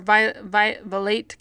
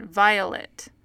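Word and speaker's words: Vilate: but anyway, so for Violate Kimball, while we were reading the Relief Society book, it's spelled V-I-O-L-A-T-E or something like that. So we realized like, they're pronouncing it Violet, Violet, Violet. They're pronouncing it Violet. And so we're convinced that her name is not Violate, that it's Violet.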